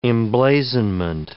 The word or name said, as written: emblazonment